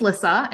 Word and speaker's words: Lissa: So my name is Lissa, and I always like to say it's Melissa without the M-E because it's not about me. So that way people know how to pronounce it.